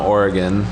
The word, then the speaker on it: Oregon: So, okay, so you're from Oregon.